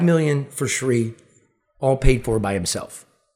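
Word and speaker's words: Thanedar: Yeah, I always mispronounce his name. – Olia? – It's Shree. – Oh, Shree. – Yeah. Is it Olia? – Olia. – Adam Olia and Shree.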